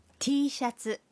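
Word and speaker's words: টি শাটসু। (Tシャツ): টি shirt শব্দটির জাপানি উচ্চারণ হল টি শাটসু।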